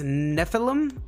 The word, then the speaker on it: Nephilim: That's actually the first word that I thought of when I was reading it, but it's Nephilim.